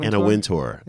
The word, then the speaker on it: Wintour: Am I pronouncing that right? Wintour. Wintour, Wintour? Anna Wintour. Yeah, okay. Wintour.